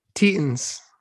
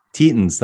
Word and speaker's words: Tetons: What the hell does Tetums mean? – Oh my god, stop it. – Sorry, I'm not saying it right. Tetums. – Tetons. – Tetons, that's it. Tetons.